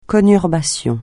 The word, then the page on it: conurbation: francais